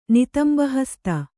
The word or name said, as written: ನಿತಂಬಹಸ್ತ